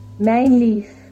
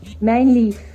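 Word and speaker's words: Mijnlieff: No sé, no sé si sabe cómo se pronuncia porque no sé qué significa el nombre, pero es M-I-J-N. – M M-I-J-N-L-I-E-F-F. – yo lo pronuncié así porque asumí que esa J venía de, alguno de esos países eh, donde la J se pronuncia como una I. – como en el Esperanto. – El Esperanto, bueno, como el croata y eso.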